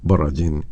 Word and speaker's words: Borodin: De Piotr Ilich Tchaikovsky se ha escuchado Cuarteto de Cuerdas en si bemol mayor por el Cuarteto de Cuerdas Borodin o Borodin en su pronunciación original.